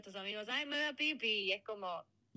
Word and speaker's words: Phi Phi: Seguro te referís a las islas Fifi, que en realidad se pronuncia más como Pipi, sin marcar la F.